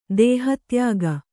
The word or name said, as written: ದೇಹತ್ಯಾಗ